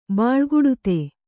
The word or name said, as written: ಬಾಳ್ಗುಡುತೆ